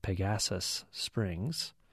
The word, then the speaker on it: Pegasus Springs: Mary says Pegasus Springs has the emphasis on the gas, so Pegasus Springs.